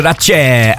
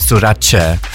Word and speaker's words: Sorace: Roberto Sorace and Joyce. It's like Versace. Versace is pronounced Versace. Sorace.